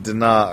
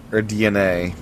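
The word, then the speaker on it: DeNA: So, Nintendo and Dana, or DNA, as it may be pronounced,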